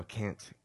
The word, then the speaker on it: Kant: No, Kant, K-A-N-T, Kant.